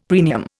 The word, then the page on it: premium: Indian